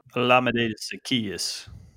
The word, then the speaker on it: Olamide Zaccheaus: Olamide Zaccheaus.